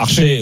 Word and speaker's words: Archer: But de Cameron Archer, que beaucoup prononcent Archer. – Archer, oui.